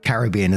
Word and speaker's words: Caribbean: And just before we go any further into the comments, I just want to say Caribbean is how we pronounce it in the UK.